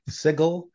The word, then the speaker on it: Sigil: I have to say it correctly. Sigil, the city of doors.